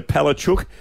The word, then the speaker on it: Palaszczuk: Premier Anastasia Palaszczuk, is how you pronounce it, wants the IndyCars back on the streets of Surfers Paradise in 2020.